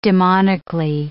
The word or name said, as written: demonically